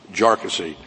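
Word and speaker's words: Jarkesy: By the way, it's pronounced Jarkissi, not a number of other ways that it's been pronounced by many.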